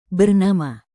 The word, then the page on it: bernama: Indonesian